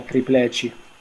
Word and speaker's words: triplete: Allora, quindi calcio 10 anni fa, l'Inter vinceva il triplete, nello stesso anno Diego Fusaro aveva 26 anni e citava Platone in un video su YouTube. – Comunque triplete è portoghese e si pronuncia tripleci.